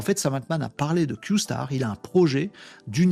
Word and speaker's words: Q-Star: Q-Star. Q étoile. Q astérix. Ça se prononce Q star, d'après ce que j'ai compris.